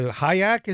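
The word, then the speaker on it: Hyak: Hayek, is that how you pronounce the team now? – Yep.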